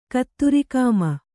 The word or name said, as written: ಕತ್ತುರಿಕಾಮ